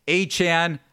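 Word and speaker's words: Achane: Tyreek Hill, Achan, I think is how he wants to have his last name pronounced moving forward, and Mostert combining for 532 total yards of offense between those three skill position players.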